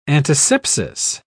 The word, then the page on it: antisepsis: British English